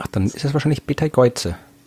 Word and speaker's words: Betelgeuse: Ich sage so gern den englischen Namen, Betelgeuse. Das klingt einfach so cool. Ist natürlich ein arabischer Name in Wirklichkeit. Aber auf Englisch spricht man ihn tatsächlich Betelgeuse aus.